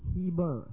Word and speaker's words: Heber: Now, the word Eber is also in the old writings pronounced as Heber, H-E-B-E-R, where they get the word Hebrew.